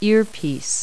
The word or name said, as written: earpiece